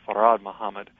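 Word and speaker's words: Fard Muhammad: The second thing is that the name Allah refers to all black men, all Muslims, but in particular refers to Master Farid Muhammad, or sometimes pronounced Farad Muhammad.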